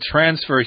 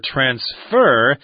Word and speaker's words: transfer: We should also mention that transfer here, or transfer, is often pronounced that way as a verb, the accent on the second syllable, but a lot of people just say transfer for both the noun and the verb.